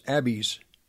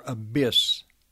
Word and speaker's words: Abys: Her maiden name was Abbeys, or Abyss. It could be pronounced either way. A-B-Y-S, Abyss. The father, his father, great-grandfather, would be Antonio Fauci von Schia Siaccia, S-C-I-A-C-C-A,